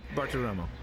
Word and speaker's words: Bartiromo: Am I pronouncing her name right? – Good enough. – Is it Bartiromo? I'm not quite sure how to pronounce. Well, how do you pronounce Maria's last name there? – Bartiromo, yeah.